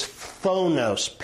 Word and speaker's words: phthonos: And so we're going to study jealousies, which is the Greek word zelos, Z-E-L-O-S, which will be very important, and envy, which is phonos, P-H-T-H-O-N-O-S, ph-th. Phonos is how you say it.